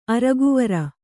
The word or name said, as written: ಅರ್ಕವಾರ